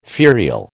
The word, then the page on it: ferial: American English